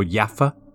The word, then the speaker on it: Jaffa: And obviously, so this story is set in Jaffa, or Jaffa as it's pronounced, which is the outskirts of Tel Aviv.